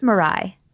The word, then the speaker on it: Murai: It's Murai. – Right, is helming a new sci-fi thriller called Man Alive. Chris wrote this up for the site, but Ben, what do we know? – Yeah, so Hiro Murai is the director of a bunch of episodes of FX's Atlanta.